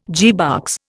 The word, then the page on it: gearbox: Indian